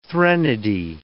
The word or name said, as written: threnody